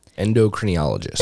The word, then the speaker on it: endocrinologist: So you would have to find an endocrinologist. A how do you say that? – endocrinologist.